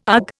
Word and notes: ugh: Indian

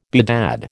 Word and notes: bedad: American English

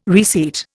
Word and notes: reseat: Indian

